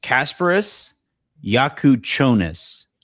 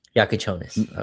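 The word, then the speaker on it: Kasparas Jakučionis: Quote, the name is pronounced Kasparis Yakuchonis. – Yakuchonis.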